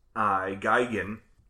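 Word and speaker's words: Gaghan: So I decided to pull up the guy who wrote this movie, which is Stephen Guygen, which is the phonetic pronunciation is on his Wikipedia page.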